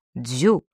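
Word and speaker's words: дзю (九): Всегда почитал число 9, и этот рассказ под названием «Город вина» у меня 9, а 9 произносится, как и вино, дзю.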